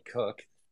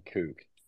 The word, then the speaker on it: Kook: Fun fact before we get started, you know, you call me Cook. This is a common thing I've noticed since Spaces took off. It's Kook. It's like a surf term. Pronunciation Kook. Everybody gets it wrong.